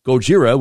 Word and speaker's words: Gojira: The project name comes from the second and third syllables of the Japanese word pronounced as Gojira, which is Japanese for Godzilla.